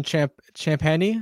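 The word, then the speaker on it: Champagnie: Justin Champagny? Is that how you say his name? – Yeah.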